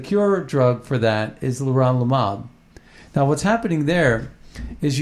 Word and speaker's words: leronlimab: The drug is called leronlimab, which is spelled L-E-R-O-N-L-I-M-A-B, leronlimab.